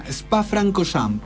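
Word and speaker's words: Spa-Francorchamps: Francochamps. Se pronunciaría. – Francochamps, pero sigue aquí, Spancro. – Ah, empieza arriba. – No empieza abajo. Ah, sería Spa Francochamps.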